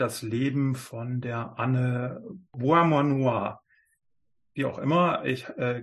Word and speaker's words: Beaumanoir: Also es geht um Anne Beaumanoir.